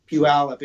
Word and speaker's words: Puyallup: Puyallup's one of those words that you can immediately tell whether the person you're talking to is a native or not, because only natives know how to say Puyallup. Everybody else mispronounces it. – Puyallup? Is that how you say it? – Yeah. Puyallup. If you're not from around here, you say Puyallup.